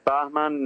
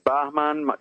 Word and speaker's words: Bahman: Just type my name, Bahman Nasiri. I will spell it again. Bahman is my first name, B-A-H-M-A-N, Bahman. And please don't call me Batman.